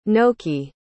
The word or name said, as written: noki